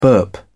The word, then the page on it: burp: British English